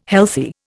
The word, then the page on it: healthy: Indian